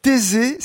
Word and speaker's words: Thésée: Thésée, c'est une commune agricole et surtout viticole.